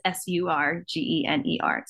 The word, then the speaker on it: Surgener: You can follow me on Instagram at Brooke Surgener. Um, my name is S U R G E N E R, Surgener.